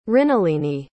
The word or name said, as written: mrinalini